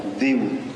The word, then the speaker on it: Daewoo: Alors Déou, c'est bien D-A-E-W-O-O. On a demandé à des Coréens comment ça se prononçait. On a cru qu'il y avait des prononciations compliquées. En fait, c'est très simple. Ça se prononce Déou, comme chez nous.